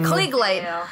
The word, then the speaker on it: Klieg: A Klieg light.